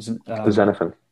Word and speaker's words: Xenophon: And I wonder, you know, what the core teaching would be from, how do you say his name again? – Xenophon. – Xenophon.